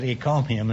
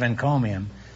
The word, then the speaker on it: encomium: For some curious reason, I said ecomium instead of encomium.